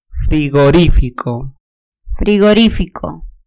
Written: frigorifico

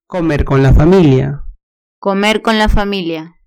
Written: comer con la familia

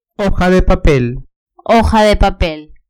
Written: hoja de papel

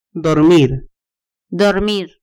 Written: dormir

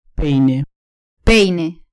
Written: peine

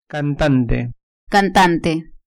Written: cantante

